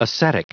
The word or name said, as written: ascetic